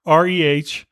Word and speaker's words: Shohreh: Shohara Agdashlo? Wow. Shohreh.